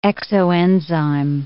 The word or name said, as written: exoenzyme